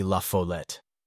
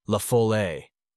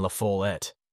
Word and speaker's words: La Follette: It is pronounced the easiest way imaginable, simply La Follette. The accent is on the foal. So many people undertake to give it a French twist and pronounce it La Follette, while others insist upon La Follette, with the accent on the et. Both of these pronunciations are out of the ordinary, and much harder to get away with than just plain La Follette.